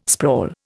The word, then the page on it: sprawl: Indian